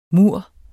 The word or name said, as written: mur